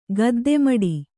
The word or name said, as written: ಗದ್ದೆಮಡಿ